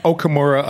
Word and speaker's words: Okamura: Okamura, I say. I think the rest of my family pronounces it differently anyhow, so it's all New Yorker also.